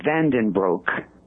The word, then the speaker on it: Vandenbroeke: It's Robbert Vandenbroeke. B-R-O-E-K-E. And again, on the website, there is a listing on the menu on the left-hand side. It says Vandenbroeke case.